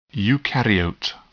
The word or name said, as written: eukaryote